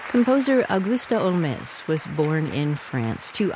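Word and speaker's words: Holmès: Originally pronounced her last name like Sherlock Holmes, but Frenchified it to Olmez after becoming a citizen of France at the age of 24.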